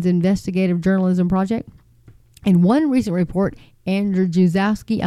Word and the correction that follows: Andrzejewski: I'm sorry, cannot say his name, folks. It is Andrzejewski.